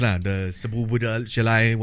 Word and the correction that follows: Szoboszlai: whatever. – It's Dominic Szoboszlai. – Okay, Dominic Szoboszlai.